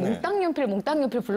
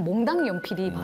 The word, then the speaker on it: 몽당연필: 사실 저희는 어렸을 때는 몽땅연필 불렀잖아요. – 네. – 근데 원래대로 하는 발음은 몽당연필이 맞아요. 표준어로.